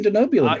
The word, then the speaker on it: Denobulan: Bravo for pronouncing Denobulan correctly.